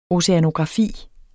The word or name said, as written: oceanografi